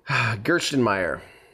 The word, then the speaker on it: Gerstenmaier: Gerstenmaier.